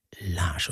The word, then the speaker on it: Lage: Julian Lage schreibt er sich, nicht verwandt mit Klaus Lage. Also, er kommt aus Kalifornien und er spricht sich Lage. Julian Lars.